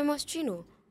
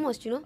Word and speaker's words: Moschino: So it was inspired by Moschino. Moschino did a long back with some teddy bear jacket or something. – Moschino. – Like who's that's it? The brand name, Moschino.